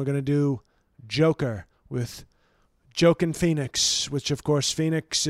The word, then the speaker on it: Joaquin: The J, I think, is pronounced like an H. – So, Joaquin Phoenix.